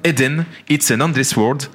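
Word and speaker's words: Eden: It's an Endless World: Ça s'appelle Eden. It's an Endless World.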